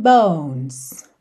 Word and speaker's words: bones: Your skin, oh yeah, your skin and bones. Bones, não pronuncie a letra E, tá bem?